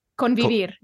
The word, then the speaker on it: convivir: Convivir, yeah.